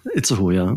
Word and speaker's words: Itzehoe: Die ist verurteilt worden vom Landgericht Itzehoe. Habe ich das jetzt richtig ausgesprochen? Ich habe erfahren, das ist dein Geburtsort. – Itzehoe, ja. – Mhm. Itzehoe, toll. – Bitte nicht Itzehoe oder Itzehoe.